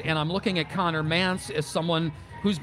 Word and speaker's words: Mantz: Now, his name is spelled M-A-N-T-Z, Mance Pain Chamber.